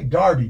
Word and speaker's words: Derby: These young guys from, they say Darby, but it's spelled Derby.